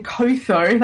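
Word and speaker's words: Qotho: And Kotho, that's how I pronounce it, it's sort of like Clotho, one of Drogo's blood riders, he's quick to point out that a Khal who cannot ride is no Khal.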